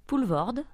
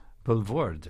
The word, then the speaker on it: Poulvorde: Did I pronounce that okay? – Poulvorde. – Poulvorde, okay.